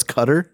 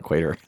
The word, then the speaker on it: Qatar: I found out it's Qatar, not Qatar the other day. So yeah, isn't that weird? – It's Qatar. – Qatar is where they had the World Cup, but the correct pronunciation is Qatar. So, how do you spell it? – Q-A-T-A-R? – Is it a Q-A-T-A-R?